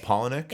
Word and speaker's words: Palahniuk: Am I saying that Palahniuk. – Right? Palahniuk. – It's Paula and then Nick. – Okay. – It says so on his website. I know because I looked. – Sure. Chuck Palahniuk.